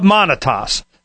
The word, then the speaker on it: Monitas: Creator of the Open Transactions Project and the co-founder of Monitas. I imagine rhymes with gravitas.